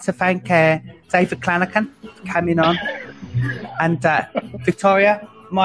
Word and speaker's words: Clanachan: We'd like to thank David Klinakin. – Sorry, brother. See, I knew I'd fuck it up. – It's Scottish. Klinakin, like a hard C.